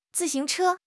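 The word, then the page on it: zi4 xing2 che1: tone 4; tone 2; tone 1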